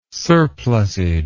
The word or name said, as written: surplusage